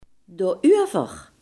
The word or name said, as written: daarover